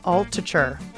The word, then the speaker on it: Altucher: Altucher. It's spelled A L T U C H E R.